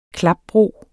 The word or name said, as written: klapbro